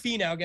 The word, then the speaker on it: Finau: But it is Finau, guys. We're still talking about here.